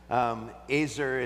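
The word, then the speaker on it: Azer (עזר): Azer is the word that's how you actually pronounce it in Hebrew, like razor, is the word that's used to describe Eve when she is given to Adam.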